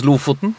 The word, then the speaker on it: Lofoten: And it's pronounced Lofoten, the Lofoten Islands.